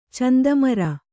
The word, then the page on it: ಚಂದಮರ: Kannada